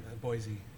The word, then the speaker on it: Boise: There's no Z in there. – Boise.